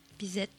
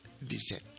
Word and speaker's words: Bizet: Bizet. – Bizet.